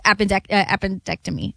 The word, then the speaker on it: appendectomy: You can't, we can't, we can't say everything's changed just because they beat the Celtics in a game seven after, you know, his appendectomy. Did I say that right? – Yeah, that one.